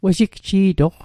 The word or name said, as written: wejigji'toq